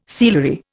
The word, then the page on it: sealery: Indian